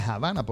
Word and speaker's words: Havana: Pero en inglés se dice Havana porque en inglés la H se pronuncia como J.